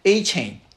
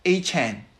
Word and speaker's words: Achane: 是两个 RB 啊， Raheem Mostert 和 Devon Achane，我都不知道，因为 Adam Schefter 和那个他们礼拜一的时候，因为他拿了四个 touchdown 之后，他自己跟联盟说他的那个名字的发音大家都给他发错了，因为他们原来官网上面是说 Achane 对吧，一个像一个锁链一样 Achane，但他说他的发音应该是 Achane，我也不知道 Okay.